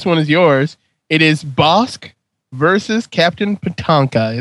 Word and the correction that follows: Panaka: Isn't that how you say his name? – Panaka, whatever.